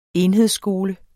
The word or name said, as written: enhedsskole